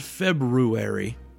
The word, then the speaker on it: February: It's spelled February, but I think that's silent. February. That's what everybody says.